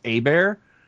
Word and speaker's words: Hebert: The last name's actually pronounced Abear.